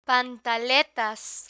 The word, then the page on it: pantaletas: Spanish